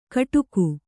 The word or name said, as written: ಕೊಡುಗೆ